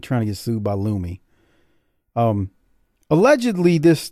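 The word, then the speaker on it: Lumé: There's a product called Lumi, L U M E, and it's got the little fancy squiggle above the E, I believe. You know, so it's pronounced as a or whatever. – Yeah, I think it's a Lumi.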